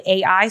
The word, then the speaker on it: Ai: It's spelled A-I. Some people say A-I, but I say I.